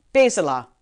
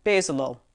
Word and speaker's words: Bezalel: Bezalel. What? You've never heard of Bezalel? I did pronounce it wrong the first time. Bezalel.